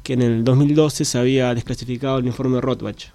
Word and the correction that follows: Rattenbach: Rottenbach, siempre lo pronuncio mal, de hecho. Y ahí, bueno... – En realidad es Rattenbach.